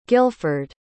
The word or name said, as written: guildford